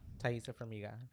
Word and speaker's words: Taissa Farmiga: Um, how do you say her name? Taissa. – Taissa Farmiga.